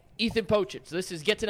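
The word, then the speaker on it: Pocic: Is that the proper pronunciation? Because I have no idea. – I think it is. – It is Pochich.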